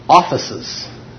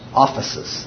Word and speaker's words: aphesis: And this is pronounced offices. It's APHESIS. Offices. That's how it's pronounced.